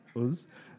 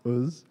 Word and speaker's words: Uz: I should pronounce it wrong. Uz. Not Oz, not the Wizard of Oz. Uz.